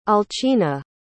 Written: alcina

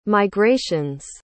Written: migrations

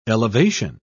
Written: elevation

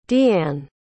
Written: deanne

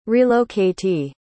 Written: relocatee

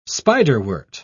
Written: spiderwort

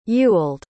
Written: Ewald

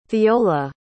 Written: theola

